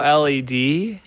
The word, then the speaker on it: OLED: OLED.